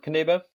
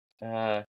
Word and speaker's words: Knibbe: Just look for Robert Knibbe. Knibbe, Knibbe.